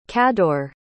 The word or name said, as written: cador